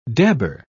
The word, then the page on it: dabber: British English